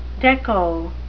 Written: dekko

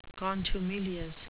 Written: contumelious

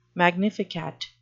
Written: magnificat

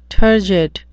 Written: turgid

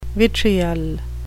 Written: vitriol

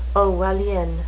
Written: orwellian

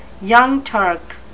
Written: Young Turk

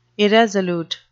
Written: irresolute

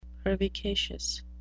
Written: pervicacious

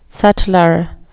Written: sutler

